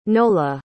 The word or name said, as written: nola